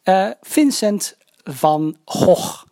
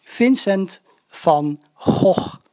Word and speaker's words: Vincent van Gogh: Vincent van Gogh. Vincent van Gogh. Vincent van Gogh.